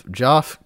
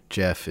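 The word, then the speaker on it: Geoff: Uh, Canadian himself, Joff Keighley, Jeff is how you're supposed to pronounce it, hosted the what eighth, ninth, tenth annual Video Game Awards, formerly mm.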